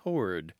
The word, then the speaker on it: toward: Yeah, pronunciation of T-O-W-A-R-D, the kind of, how shall we put this, classic, maybe even elitist pronunciation is toward, T-O-R-D. But many, many, many, many people throughout the English-speaking world, throughout the Anglosphere, say toward. They say it like it's written. They pronounce the W. It's two syllables, not one.